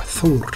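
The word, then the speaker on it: Þórr: トールっていうのは、はい、どんな神様なんですか、はい、はい、北欧の古い言葉の発音ではソウルとなります。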